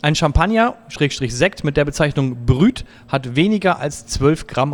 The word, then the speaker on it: brut: Ja, pass auf, es heißt nicht Brü. – Oh. – Nein, es heißt Brüt.